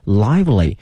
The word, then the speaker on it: lively: The band used to tell lively stories 啊，来讲述那些非常生动鲜活的那些故事，鲜活生动。今天我们学一个词叫 live L-I-V-E 后面加个 ly L-Y 哈 lively，不要把这个 L-I-V-E 呢念成 live，它是一个 live 后面加 L-Y lively 表示活泼的生动的哈。 lively story of 农村的生活 of rural life rural，今天学另外一个词农村的 R-U-R-A-L